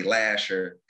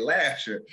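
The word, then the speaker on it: Lahser: So I do a lot of my work right in Northwest Detroit, 6 Mile, 7 Mile, I'm on Finkel, Evergreen, you know, I say Lasher, because that's, you know, where I'm from. – We say it. – That's right. That's how you say it, Lasher.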